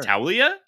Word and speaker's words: Taulia: Taulia? – Sure.